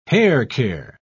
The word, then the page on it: haircare: British English